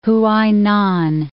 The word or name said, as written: Huainan